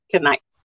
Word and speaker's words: Kenites: He didn't want to destroy the Kenites, right? Did I say it right? Kenites. Yeah. And Saul smote the Amalekites from Havilah until thou comest to Shur.